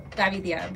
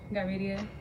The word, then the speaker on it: Gavidia: Hi, I'm Andrea Gavidia. – Gavidia, is that how you say it? – Yeah, well, it's Gavidia, but most people can't say that, so Gavidia.